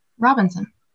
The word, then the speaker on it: Robinson: Robinson.